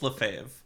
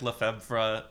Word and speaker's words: Lefebvre: Next month, I'm hosting with Sarah Lefebvre. Sarah Lefebvre. I've said it wrong probably. – Mm-hmm. I think it's Lefebvre, but I said it like Lefebvre until she told me that like a month ago.